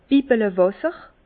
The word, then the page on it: Bibbelewàsser: Bas-Rhin; Herrlisheim